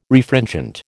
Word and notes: Refringent: American English

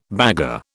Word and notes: bagarre: British English

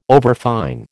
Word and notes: overfine: American English

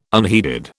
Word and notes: unheeded: British English

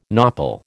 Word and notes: nopal: American English

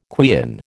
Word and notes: quean: American English

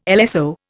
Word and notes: LSO: Indian

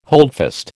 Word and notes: holdfast: American English

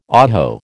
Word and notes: oho: American English

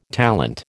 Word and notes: talent: American English

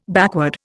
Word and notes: backward: Indian

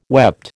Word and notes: wept: American English

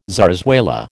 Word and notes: zarzuela: American English

